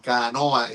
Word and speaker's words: canoa: Entonces, Colón decía, en su diario decía, ellos le dicen a esa fusta, una fusta es una embarcación allá en Europa, ellos le llaman canoa, con la última sílaba lengua, luenga, larga, esa canoa.